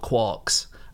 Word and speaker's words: quark: And then he saw the word quark in Finnegan's Wake, where it clearly rhymes with Mark. But even though he spelt it in the same way that Joyce did, he always pronounced it quark.